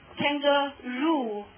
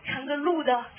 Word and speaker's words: kangaroo: 咱们那个代词怎么说？Kangaroo，对不对？看它发音 kangaroo 哈，扛个鹿的，是不是？